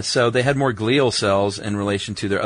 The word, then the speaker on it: glial: Specifically, the glial, G-L-I-A-L is how I'm pronouncing it, glial cells? – Are, yes. – Is that correct? – Yeah, you're right.